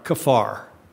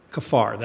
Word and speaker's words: kaphar: The primary word is spelled in English K-A-P-H-A-R, kafar, kafar. That's the Hebrew word.